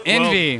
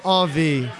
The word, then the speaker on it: envie: Envy. – Well, they say envy because it's spelled E N V I E, envy, but it's pronounced On V. It's a Cajun French word for a craving, as in I have an On V for hops.